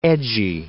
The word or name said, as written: edgy